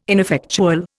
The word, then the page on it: ineffectual: Indian